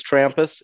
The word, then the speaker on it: Trampas: I'm used to going last because nobody can pronounce my first name. So thank you for letting me go last. But it is Trampas. It's not as hard as it looks.